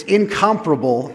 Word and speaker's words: incomparable: We think of that as incomparable, but the, the, the emphasis should be on the other syllable. So it's really one of those words you want to make sure you have the right emphasis on the right syllable.